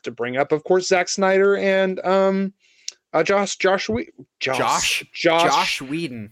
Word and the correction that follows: Joss Whedon: I can never say his name right. Joss Whedon.